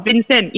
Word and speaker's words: Vincennes: Vincennes. V-I-N-C-E-N-N-E-S. Oh, God. Wait, what? – Vincennes. It's a place, yeah? – Vincennes.